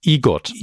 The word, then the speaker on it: EGOT: Mit dem Emmy-Gewinn ist Elton John übrigens der neueste EGOT wird es ausgesprochen.